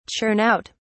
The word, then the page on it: churn out: British English